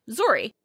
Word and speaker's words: Zorrie: Zorrie.